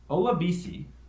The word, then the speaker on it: Olabisi: We have rookie receiver Olabisi. I hope I said that right, because I like this guy, Johnson. – That is correct, actually.